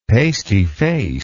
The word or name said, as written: pasty faced